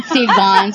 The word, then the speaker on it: Sylvain: Ok, parece Silvan. – Silvan, se oye como Silvan.